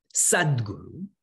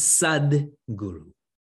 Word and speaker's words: sadguru: Now, some of you may have seen the word sadguru. What that means is sat, S-A-T. Because of Sanskrit grammar rules, it becomes sadguru. Please do not pronounce it as sad guru.